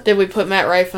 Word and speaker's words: Matt Rife: Matt Rife.